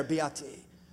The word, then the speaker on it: beati: The Beatitudes are from the Latin word beati. If you say it like with a little, you know, Italian flair, beati, forget about it, right?